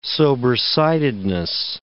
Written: sobersidedness

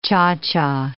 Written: cha-cha

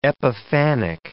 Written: epiphanic

